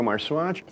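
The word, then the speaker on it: Marxuach: My name is Sergio Marswatch. – Say your last name again. – Marx, like Karl Marx, and Swatch, like the watch.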